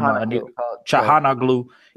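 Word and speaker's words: Çalhanoğlu: Chana Glu. I can't pronounce this guy's name. – Calhanoglu.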